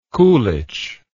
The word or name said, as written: kulich